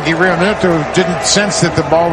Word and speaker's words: Realmuto: Realmuto? Is that how you say it? Yeah. Realmuto.